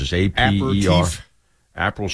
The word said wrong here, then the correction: Aperol: Spritz. – Oh, no. – A-P-E-R. O-L, Spritz. – Aperol.